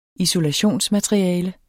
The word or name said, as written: ISOLATIONSMATERIALE